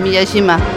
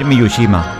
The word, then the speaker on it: Miyajima: Miyajima. ¿Lo he dicho bien, Nina? – Lo has dicho bien, Miyajima. – Es que yo tenía la manía de decir Miyushima. Es Miyajima.